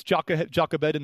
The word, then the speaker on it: Jochebed: He had his mother Jochebed save his life. That's Jochebed in the Hebrew pronunciation.